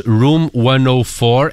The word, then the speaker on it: Room 104: Também pela HBO, a outra sugestão do Tiago hoje, Room 104, é assim que se pronuncia? – É, tal e qual, aliás, é quarto já.